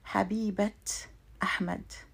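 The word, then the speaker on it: Habibat Ahmad (حبيبة): However, if this word is followed by a noun, then I have to pronounce the ta marbuta as a T. So for example, I could say Habibat Ahmad, the loved one of Ahmad, meaning his girlfriend.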